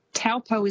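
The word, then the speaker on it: Taupō: but famous spots including Taupo. So quite often you'll hear that said as Taupo. – Yes. – It's almost how it's spelt, but that Taupo is actually pronounced Taupo.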